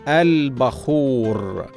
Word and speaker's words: البخور: من الكلمات المتداوله قديما وحديثا لرائحتها الجميله البخور هكذا تنطق البخور بفتح الباء ولكن البعض ينطقها بغير ذلك وهذا خطأ شائع والصواب نطقها بفتح الباء البخور